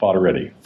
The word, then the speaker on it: Foderetti: It's pronounced Fodoretti.